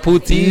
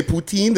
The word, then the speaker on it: poutine: Oh, they got poutine. – He's not saying it right. What did I say? Poutine? Did I say Torontonian wise? – You said Toronto. Yeah, say like a month from.